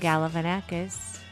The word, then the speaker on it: Galifianakis: Galifianakis. Galifianakis. Am I saying that right? – Yep. Yes. Yeah.